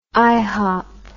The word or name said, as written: ihop